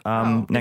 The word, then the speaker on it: NXIVM: Yes, this one's called like NXIVM. – Yeah, and it's just pronounced Nexium.